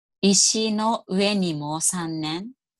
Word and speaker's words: 石の上にも三年: 石の上にも三年。 – Okay, we're going to have to do this slower. – 石の上にも三年。